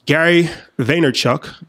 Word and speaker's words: Gary Vaynerchuk: Yeah, so, you know, Gary Vaynerchuk.